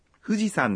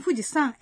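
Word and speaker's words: 富士山: San em Fujisan é igual ao honorífico san colocado depois dos nomes das pessoas? – Não, mas a pronúncia é a mesma. Montanha em japonês é yama. No entanto, a pronúncia muda para sam quando essa palavra é colocada depois do nome de algumas montanhas.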